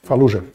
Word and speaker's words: Fallujah: Faluja.